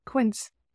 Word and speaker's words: Quince: Quince, it rhymes with Prince.